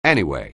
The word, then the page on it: anyway: anglais